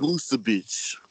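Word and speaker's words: Vučević: You got Vucevic. Y'all know what I'm talking about. I probably butchered that. – I like him. – Vucevic. – There we go.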